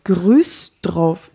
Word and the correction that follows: Güstrow: Güstrow. Wie schreibt man das? G-U-S-T-R-O-W. Güstrow. Güstrow.